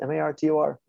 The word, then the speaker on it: Martorana: It's very phonetic. It's said just like it's spelled, Martorana.